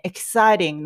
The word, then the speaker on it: exciting: えー、この発音なんですが、この t の部分ね、 exciting の t の部分なんですが、えー、 exciting, exciting, こう、つって、あの、 t を発音してももちろんいいんですけど、